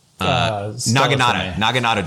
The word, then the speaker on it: naginata: I love the sequence on the naginati. Oh, I always say that wrong. The long spear with the knife, the one that's the traditional weapon of like the women. Naginata do. Yeah.